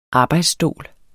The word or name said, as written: arbejdsstol